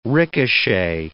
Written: ricochet

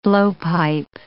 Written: blowpipe

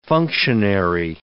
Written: functionary

